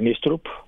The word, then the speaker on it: Νίστρουπ: Λοιπόν, ήρθε χθες ο Νίστρουπ, όπως προφέρεται τελικά το όνομά του. Θα τον ρωτήσω κιόλας την επόμενη εβδομάδα όταν τον δω στην παρουσίαση. Θα τον ρωτήσω φυσικά, αλλά νομίζω είναι Νίστρουπ τελικά και έκατσε η μπίλια.